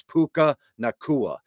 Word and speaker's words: Puka Nacua: I know it's I, how you pronounce it. it. It's Puka Nacua.